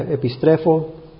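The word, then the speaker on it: epistrefo (ἐπιστρέφω): El griego se pronuncia epistrefo.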